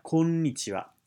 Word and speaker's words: konnichiwa: And in Japanese, the konnichiwa has an elongated n sound, and so you have konnichiwa is how you say it properly.